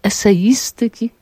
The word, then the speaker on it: eseistyki: I postanowiłam napisać pracę doktorską na temat eseistyki, ładnie akcentujemy, Czesława Miłosza.